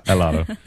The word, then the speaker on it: helado: Well, the H is silent. – Yeah, helado.